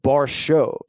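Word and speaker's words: Borshchev: It's, so the surname is spelled Borshev, but it's pronounced Borshev. So we'll see if the producers request a revoice, but my phonetics say Vyacheslav Borshev.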